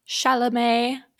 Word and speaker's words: Chalamet: I still call him Timothée Charmander or Salamander. I don't know how to pronounce his name. – Chalamet.